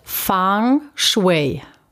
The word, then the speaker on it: Feng Shui: Und jetzt sprich's nochmal aus, wie es in, in Asien ausgesprochen wird. Fang Shui.